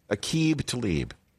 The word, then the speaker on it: Aqib Talib: So Aqib Talib, and one person who I think is very pleased that he is retired, is Michael Irvin, who could never pronounce his name. Sometimes it would be Aqib Talib. You know, I mean, it's Aqib Talib from Kansas.